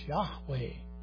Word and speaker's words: Yahweh: If you say it like it's supposed to be, because it starts with a Y, it is Yahweh.